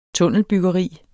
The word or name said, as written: tunnelbyggeri